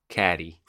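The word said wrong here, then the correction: Cady: It just throws me off because it's spelled with the C. I don't know why I look at it and I'm like, I want to say Caddy, but Katie.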